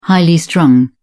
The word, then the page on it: highly strung: British English